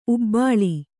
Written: ಉಬ್ಬಾಳು

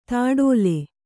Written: ತೊಟ್ಟಿಲ್

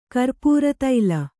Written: ಕರ್ಪೂರತೈಲ

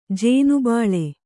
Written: ಜೋನೆಬೆಲ್ಲ

